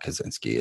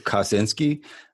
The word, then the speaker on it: Kosinski: It's not Kaczynski. It's Ted Kaczynski. – It's Joseph Kosinski.